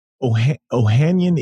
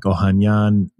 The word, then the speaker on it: Ohanian: Okay. Ohanian is what I say. I feel like there's a much more Armenian way of doing it, which is like Ohanian, but I just say Ohanian, Alexis Ohanian.